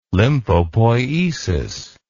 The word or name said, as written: lymphopoiesis